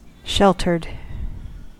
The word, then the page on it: sheltered: American English